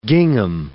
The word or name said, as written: gingham